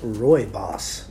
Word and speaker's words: rooibos: And it's pronounced rooibos.